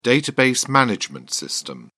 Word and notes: database management system: British English

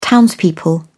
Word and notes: townspeople: British English

